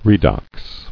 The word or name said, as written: Oxidating